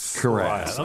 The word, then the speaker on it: cuirass: Cuirass. – I'm thinking cuirass. – Like caress.